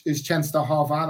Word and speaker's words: Częstochowa: But the town is Częstochowa, that's what it's called.